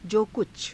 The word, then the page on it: jo kucha: Hindi